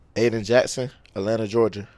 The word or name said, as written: Ayden Jackson